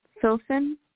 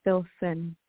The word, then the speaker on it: Philson: Philson. – Philson? – Like Philip? Yeah, Philson.